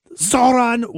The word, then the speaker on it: Zohran: Zohran was elected. Cut five. – We have chosen instead to turn towards making a new future for all of us. In Zohran Mamdani, we have chosen a mayor who is relentlessly dedicated to making life not just possible, but aspirational for working people.